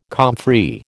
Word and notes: comfrey: American English

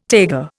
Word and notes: taiga: Indian

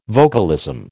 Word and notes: vocalism: American English

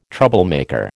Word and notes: troublemaker: American English

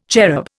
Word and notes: cherub: Indian

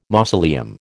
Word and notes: mausoleum: American English

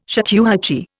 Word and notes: shakuhachi: Indian

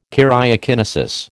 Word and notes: karyokinesis: American English

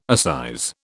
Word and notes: assize: British English

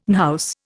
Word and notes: unhouse: Indian

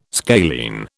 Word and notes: scalene: British English

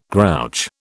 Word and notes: grouch: British English

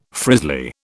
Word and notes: frizzly: British English

